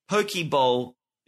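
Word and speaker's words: poke: Mookie owns a Pokeball business. And I guess that's how you say it, but it's not as in like the Pokemon ball, but the food, the Hawaiian food.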